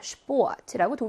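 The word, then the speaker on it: Sport: 뭐라고 읽어야 되나요? 슈포트라고 독일어식으로 발음 잘 해주셔야 합니다.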